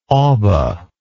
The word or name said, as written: oba